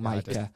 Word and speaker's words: Micah: Like Mika and or Micah. I always get his name wrong. – Micah.